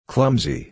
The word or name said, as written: clumsy